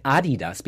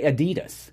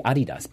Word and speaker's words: Adidas: That's, and I really respect him even more, Jeff Goldblum, if you can hear it, that you said Adidas, because that's the proper way of saying it. – Right. – Sorry, I don't want to blame anyone. You can do whatever, but a lot of Americans say Adidas. But it's actually Adidas.